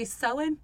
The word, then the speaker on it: Samhain: It's actually Sewin. We're partway through this episode and I'm realizing it is called Sewin, so I apologize for all the people that I just called.